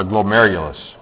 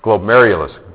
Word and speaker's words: glomerulus: All right, so we got to practice pronouncing this because you might have to spell this sometime. So this is glomerulus. Glomerulus. All right, so let's all say it together. It's glomerulus. And if you put it all together, it is glomerulus.